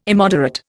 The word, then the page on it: immoderate: Indian